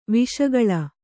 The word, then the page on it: ವಿಷಗಾಳಿ: viṣa gāḷi